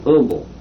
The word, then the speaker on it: Humble: That's the reason why it's called Humble and not Humble. – Okay. – Humble. You pronounce the H.